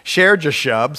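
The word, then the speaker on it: Shear-jashub: Then the Lord said to Isaiah, go out now to meet Ahaz, you and Sher-Shajab, I don't even know how to say it, Sher-Jashub, sorry about that,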